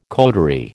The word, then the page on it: coterie: American English